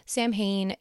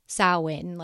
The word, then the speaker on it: Samhain: but a lot of people pronounce it Samhain. It's not completely wrong, but if you want to, kind of pronounce it like the Celts would, it's closer to Sow-en, like sow, like a cow, and Sow-en.